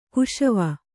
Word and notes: ಕುಶವ: kuśava

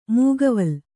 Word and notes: ಮೈಗಾವಲು: Kannada; maigāvalu

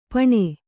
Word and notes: ಪಣೆ: Kannada

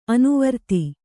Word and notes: ಅನುವೃತ್ತಿ: Kannada

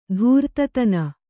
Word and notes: ಧೂರ್ತತನ: dhūrtatana

